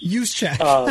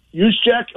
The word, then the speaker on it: Juszczyk: Juszczyk. – I can barely pronounce my own last name, which has Juszczyk. A lot of consonants in it also. Juszczyk?